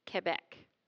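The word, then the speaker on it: Québec: That's how they say it, Quebec.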